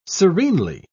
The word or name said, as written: serenely